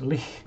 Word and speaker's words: lich: Very, very similar magics, but um, you know, greater mummies are normally high, uh, high priests, ancient high priests, and leashes. Lich is the uh, is proper pronunciation.